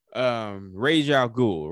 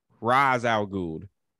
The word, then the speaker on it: Ra's al Ghul: But anyway, Ra's al Ghul, right? Or Ra's al Ghul. They changed the pronunciation of his name for the movies. Ra's al Ghul.